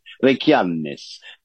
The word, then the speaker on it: Reykjanes: Done obviously with a more of an Icelandic pronunciation, Reykjanes, which is close to wiping out the town of Grindavik.